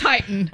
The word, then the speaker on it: Titan: No, because there's only one T, so that means it's a long I. – Uh-oh. – So it's Titan.